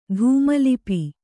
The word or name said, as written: ಧೂಮಲಿಪಿ